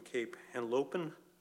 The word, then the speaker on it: Cape Henlopen: I graduated from Cape Henlopen, am I pronouncing that correctly? – Yes.